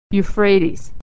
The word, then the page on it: euphrates: y